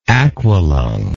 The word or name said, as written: aqualung